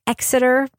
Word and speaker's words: Exeter: Laura, are you impressed with my pronunciation of Exeter, by the way? – I am. Thank you. There's no eggs in Exeter.